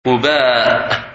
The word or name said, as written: Quba